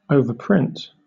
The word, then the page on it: overprint: British English